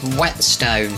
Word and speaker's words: Whetstone: On the A109 Oakley Road North in Whitestone, it's blocked due to an accident between Middleton Park and Oakley Park South. Now, where on earth is Whetstone? I mispronounced that, but it is Whetstone.